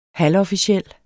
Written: halvofficiel

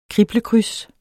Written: kriblekryds

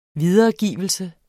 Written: videregivelse

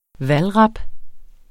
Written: valdrap

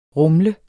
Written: rumle

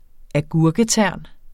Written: agurketern

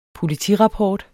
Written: POLITIRAPPORT

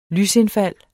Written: lysindfald

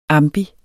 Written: ambi

